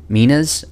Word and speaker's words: minas: You can say minas. I think that's, I guess, just the way that I've most often heard it pronounced. It's a double consonant at the beginning in the Greek, M-N-A-S, minas, but that just doesn't sound like normal English, so it's fine to just say minas, even though it sounds silly if you know how it's actually spelled.